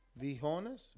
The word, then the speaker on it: viejones: He said, viejones. Right? That's what it says? Saludos, viejones? – Let me see. – Or am I pronouncing that wrong? Viejones? Viejones.